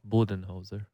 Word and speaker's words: Budenholzer: Say his name properly. Come on. – Who? – Budenholzer. – Budenholzer. Mike Budenholzer. 2018.